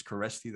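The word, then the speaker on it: Kereszti: The beauty and the curse of my last name is the curse, it's nobody can pronounce it. My last name is Keresti, that's K-E-R-E-S-Z-T-I.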